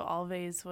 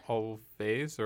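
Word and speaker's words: Alvvays: I heard Always, but the reason we're arguing is because the band's name is spelled Alvvaways, which is a way that they wanted to just I wasn't sure if that was a typographic thing that was supposed to be pronounced like a W because the two V's next to each other looked like a W.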